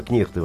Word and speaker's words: кнехты: А, вот, не кнехты, а кнехты, во-первых. Кнехты.